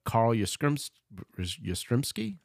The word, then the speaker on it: Carl Yastrzemski: I don't remember it at all, but Carl Yastrzemski, that's how you say it, right? – Mm-hmm.